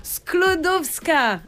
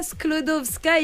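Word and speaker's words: Skłodowska: Sklodowska. Maria Sklodowska.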